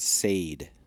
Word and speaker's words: Saeed: He, he pronounces Nadia Nadia, but he pronounces Saeed as, as Said. – Said? Yeah, like okay. said. Said. Okay. Yeah. yeah. Okay. No, it's like said.